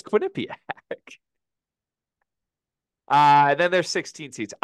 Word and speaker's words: Quinnipiac: Quinnipiac? – Quinnipiac. – You're just putting a lot of emphasis on the N. Quinnipiac. – Quinnipiac. – Quinnipiac. – It's Quinnipiac.